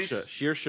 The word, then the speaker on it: Saoirse: It's Sorry, Saoirse Ronan.